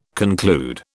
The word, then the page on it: conclude: British English